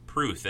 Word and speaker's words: Pruth: Pruth, as in it rhymes with truth.